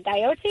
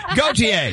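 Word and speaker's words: Gaultier: Coyote? – Coyote? – Gautier? – Gautier. – Yeah. – There you go.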